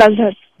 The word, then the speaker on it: Nazhat: My name is Nazhat. – Nazhat. – Nazhat, beautiful name. – Beautiful name. Yeah. Nazhakat. – Thank you. – Achha, aapne kuch first time someone is pronouncing it right, so I have to return them back telling thank you.